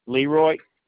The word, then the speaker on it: Leeroy: Leroy, yes, L-E-E-R-O-Y, Eads, E-A-D, as in dog, S as in Sam.